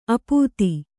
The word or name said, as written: ಆಪಾತ